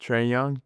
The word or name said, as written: Trae Young